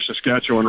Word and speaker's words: Saskatchewan: So give it up for our Saskatchewan Rough Riders.